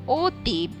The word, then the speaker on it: অতীব: এই কারণে অতীব বলা হয়, অতীব নয়। স্বরেয় ত দীর্ঘিকার ব, অতীব, অতীব নয়।